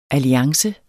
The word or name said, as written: alliance